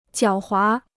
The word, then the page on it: 狡猾: jiǎo huá